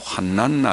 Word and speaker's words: 환난: 환난 날에, 환난 날에, 환난이라고 읽는데 환난 날에, 환난 뒤에 두 번째 글자에 니을로 되는 단어도 있죠. 그러나 환난, 우리가 우리의 그 한자말이나 한글이나 환난 그렇게 읽는 것이 옳은데, 환난 날에 나를 부르라.